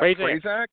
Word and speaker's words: Prazak: And, of course, Stephen – how do you say his last name? I'm going to mess it up. – Prazak. – Is that right? Yeah.